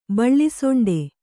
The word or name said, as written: ಬಿಳಿಸೊಂಡೆ